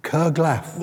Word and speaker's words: curglaff: Now, how would you spell it? – C-U-R-G-L-A-F-F. – Kerglaf? – Yes.